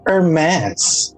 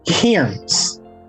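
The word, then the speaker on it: Hermès: Honey, that's Hermes, okay? It's not Hiram's, okay? – Okay, you have to pronounce it correctly. Hermes.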